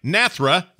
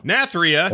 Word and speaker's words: Nathria: Castle Nathria. Am I saying it right? – Nathria. – Nathria.